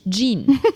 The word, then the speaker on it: Jean: J-E-A-N. – J-E-A-N. Jean. – Ich glaube, das würde er durchgehen lassen. Er hat nämlich gesagt, so wie in Jean-Pierre, nicht wie in Jeans, also nicht Jean.